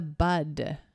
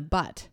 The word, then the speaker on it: bud: I think it's idioms, but so the first one is nip it in the bud. B U D. That is the correct way to say that phrase. Again, it is nip it in the bud. I have forever said nip it in the butt.